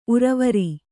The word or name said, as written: ಊರ್ವರೆ